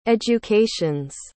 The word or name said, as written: educations